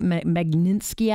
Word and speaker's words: Magnitsky: So we've got, it's the Magnitsky Act, right? Magnitsky Act.